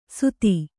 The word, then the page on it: ಸುಧಿ: Kannada